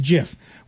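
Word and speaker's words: GIF: It's pronounced Jif.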